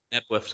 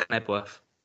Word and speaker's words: Knebworth: I used to purposely pronounce, like, silent letters as well. – Yeah. – Well, I have to go to Nebworth sometimes, so... We purposely call it Knebworth.